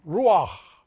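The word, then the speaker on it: ruach (רוח): Because spirit and wind in Hebrew has the same pronunciation, ruach.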